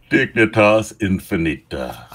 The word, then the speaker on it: Dignitas Infinita: And we're going to talk more about that document that's coming out of Rome, the Dignitas Infantia. I didn't take Latin. Pronounce that for me. – Dignitas Infinita.